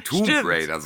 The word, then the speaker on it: Tomb Raider: Es heißt ja Tomb, Tomb Raider sogar. Ich habe es, glaube ich, 20 Jahre falsch gesagt. Es heißt Tomb Raider. Nicht der Tom.